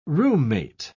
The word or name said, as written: roommate